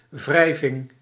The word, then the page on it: wrijving: /ˈvrɛi̯vɪŋ/